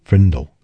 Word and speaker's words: Frindle: Nick grabbed the dictionary and leafed to page 541, his heart pounding, and there, between frimmel and fringe, he read Frindle, pronounced Frindle.